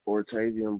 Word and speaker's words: Ortavian: Ortavian Brazelton. – Could you spell it, please? – O-R-T-A-V-I-A-N.